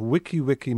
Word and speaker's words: wiki-wiki: I got an email from Kate, who writes, "The pronunciation of wiki-wiki, meaning quick, is wiki-wiki in Hawaiian. The I's are pronounced like long E's.